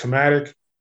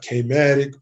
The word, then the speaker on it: KMATIKC: So that's, it's pronounced Kematic, not K-matic, but Kematic. You know, we have an artistic spelling of it, but yes, Kematic.